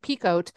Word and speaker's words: Picoult: And I always thought it was like Picoult, but I saw her on TikTok and she said Picoult. And I was like, oh, okay, it's Picoult.